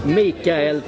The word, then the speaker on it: Mikael: How would you say Michael in Swedish? Tapper? It would be Mikael Tapper.